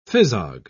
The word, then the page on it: phizog: British English